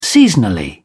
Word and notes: seasonally: British English

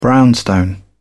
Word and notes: brownstone: British English

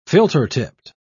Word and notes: filter-tipped: British English

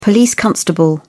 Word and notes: police constable: British English

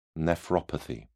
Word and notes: nephropathy: British English

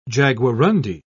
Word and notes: jaguarondi: British English

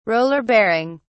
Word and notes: roller bearing: British English

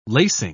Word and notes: lacing: British English

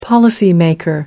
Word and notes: policymaker: British English

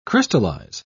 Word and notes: crystalize: British English

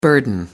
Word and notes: burden: American English

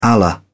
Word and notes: allah: British English

